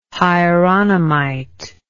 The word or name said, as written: hieronymite